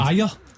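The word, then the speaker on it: Ajer: how you actually pronounce it in your mind? – Ayer. – Christopher Ayer. – And to you, for years, Ager. Inexplicably. – Oh, Ager.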